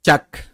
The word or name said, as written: Chak